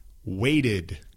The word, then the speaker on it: waited: Luego hay otros, por ejemplo, déjeme pensar, waited, created, floated, cosas así que tienen dos sílabas, ¿sí? O sea, la ed crea una sílaba separada.